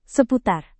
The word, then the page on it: seputar: Indonesian